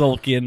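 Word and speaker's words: Tzolk'in: That was Zolkin. That was TZ. – Silent T.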